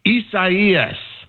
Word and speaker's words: Isaias: We're not really feeling any direct impact from Isaias. – Good, you said it right. I was trying so hard to say it right all day.